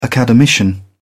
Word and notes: academician: British English

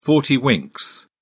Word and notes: forty-winks: British English

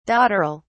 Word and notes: dotterel: British English

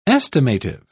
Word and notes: estimative: British English

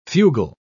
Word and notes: fugle: British English

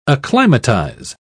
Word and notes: acclimatise: British English

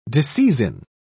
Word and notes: disseizin: British English

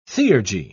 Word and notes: theurgy: British English

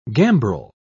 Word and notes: gambrel: British English

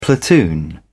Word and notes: platoon: British English

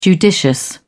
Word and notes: judicious: British English

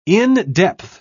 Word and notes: in-depth: British English

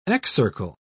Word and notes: excircle: British English

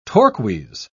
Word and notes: torques: British English

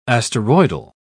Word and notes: asteroidal: British English

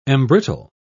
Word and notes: embrittle: British English

